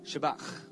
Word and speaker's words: Shabbat: Shabbat. – Shabbat. – That's how you pronounce it. – Dat is hoe je het uitspreekt in original Hebrew.